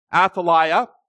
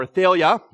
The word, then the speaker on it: Athaliah: Inspired by Satan and his attack on the promised seed, Queen Athaliah, or Athaliah, depending on how you want to pronounce it, the daughter of Ahab and Jezebel, she did the unthinkable.